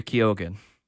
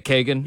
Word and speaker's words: Keoghan: Did I say his name right, by the way? How Kagan. Am I supposed to say that? Kagan? – Yeah. – Keoghan.